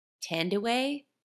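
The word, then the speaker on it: Thandiwe: I believe Tandaway is how you say her name. – Oh, I said Tandy Newton. But So she added the W back in because that's her like birth name.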